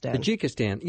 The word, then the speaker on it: Tajikistan: Am I saying that right? – Tajikistan.